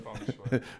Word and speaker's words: feng shui: It's just got bad feng shui. Is that how you pronounce it? – Feng shui.